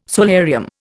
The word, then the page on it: solarium: Indian